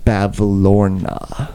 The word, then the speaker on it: Bavlorna: Your sense of direction was stolen by a hag named Bavlorna. – Whoa, you need to spell that for me. – That is B-A-V... L-O-R-N-A.